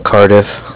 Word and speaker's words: Cardiff: Yeah, it's Cardiff. – How do you spell that? – It's C-A-R-D-I-F-F, like different.